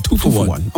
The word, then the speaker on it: Two for One: It's pronounced just like that. Two for One.